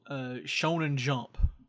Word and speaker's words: Shonen Jump: So there's this magazine called Shonen Jump.